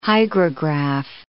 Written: hygrograph

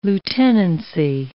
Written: lieutenancy

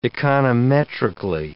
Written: econometrically

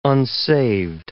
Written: unsaved

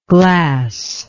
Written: glasslike

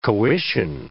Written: coition

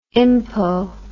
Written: impo

